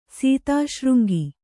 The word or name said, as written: ಸೀತಾಶೃಂಗಿ